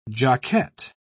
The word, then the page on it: jockette: British English